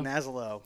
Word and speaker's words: Nasello: A lot of people say it Nocello. – Nocello? – Nocello. – Okay. – It sounds just the way it's spelled. – Yeah. – N-A-S-E-L-L-O.